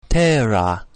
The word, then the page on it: terra: English